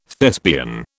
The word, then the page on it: Thespian: British English